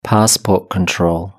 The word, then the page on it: passport control: British English